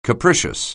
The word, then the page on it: capricious: anglais